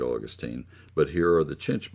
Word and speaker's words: chinch: And she didn't pronounce it quite right, so I said, no, it's C-H-I-N-C-H, chinch bugs.